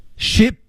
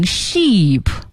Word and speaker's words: Ship: Ship to Kenya. – Ship to Kenya. – Ship to Kenya. – Ship to Kenya. – Ship to Kenya. – Ship to Kenya. 千万不要读成 sheep, 错了啊。 Ship to Kenya. Sheep, 那是绵羊啊 ,S-H-E-E-P.